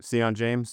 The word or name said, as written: Sion James